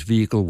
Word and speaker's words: vehicle: Their preferred pronunciation is vehicle without the H.